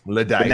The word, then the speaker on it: Luddite: So everybody give Andy a round of applause and tweet at Greg that he's a stupid Luddite. – Luddite. – Now spell it L A space dite. D-I-T.